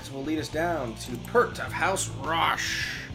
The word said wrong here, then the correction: Roosh: All right. – Roosh. Sorry. – That's how you pronounce it.